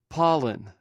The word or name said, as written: palin